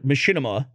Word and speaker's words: machinima: And the word machinima, which that's the way I pronounce it. – That's the correct way. – It comes from machine and cinema. So it all depends on when you start the transition from one word to the next.